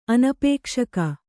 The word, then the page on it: ಅನಪೇಕ್ಷಕ: anapēkṣaka